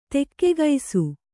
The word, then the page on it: ತಗ್ಗಿಸು: taggisu